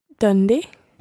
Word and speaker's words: donde: Donde.